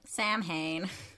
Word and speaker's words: Samhain: Yep, Samhain.